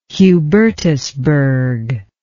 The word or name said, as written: Hubertusburg